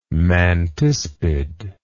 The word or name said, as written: mantispid